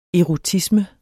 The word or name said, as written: erotisme